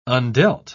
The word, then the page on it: undealt: British English